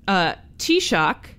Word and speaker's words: Taoiseach: I had to ask how to pronounce that, but I think I got it right. – It's a fun word, though. – Yeah, because it's spelled very different from Taoiseach. – Yes. – For me, a non-Gaelic speaker.